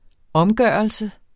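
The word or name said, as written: OMGØRELSEN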